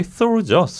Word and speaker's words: Thor: 이게 영어식 발음이 Thor죠. Thor. 아직도 어색해요. 토르가 좋아요. – 토르가 편한데.